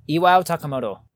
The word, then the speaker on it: Iwao Takamoto: Iwao Takamoto.